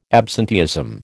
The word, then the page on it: absenteeism: American English